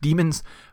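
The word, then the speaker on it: daemon: Daemons are neutral evil, and it's probably actually pronounced demons, but for the sake of, making a distinction between them in an audio format, I'm saying daemons.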